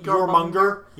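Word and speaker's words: Jörmungandr: It's like Jormungandr.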